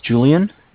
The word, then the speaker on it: Julian: All right, my name is Julian. It's J-U-L-I-A-N. – Okay. – I'm calling with Bank of Cardiff. Cardiff is spelled C-A-R-D-I-F-F, as in Frank Frank.